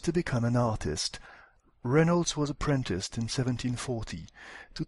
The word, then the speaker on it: Reynolds: Reynolds was born in Plympton. Ici, il fallait noter l'accent sur la première syllabe de Reynolds, juste avant le R, le mot born, juste avant le B, et la première syllabe de Plympton, juste avant le premier P.